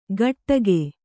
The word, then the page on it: ಗಟ್ಟಗೆ: Kannada